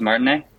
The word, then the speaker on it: Martinet: Charles Martinet.